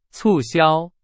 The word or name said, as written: 促销